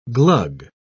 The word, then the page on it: glug: British English